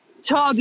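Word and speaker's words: Zhao: Chloe Chao Chao diye okunuyor.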